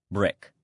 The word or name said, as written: brick